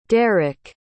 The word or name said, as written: derick